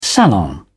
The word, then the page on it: salon: British English